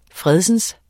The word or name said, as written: fredsens